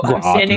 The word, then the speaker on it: Groton: The Google pronunciation guide for Groton spells it G-R-A-A. T-N, Groton. So Groton. I'm standing by my pronunciation. Google agrees with me.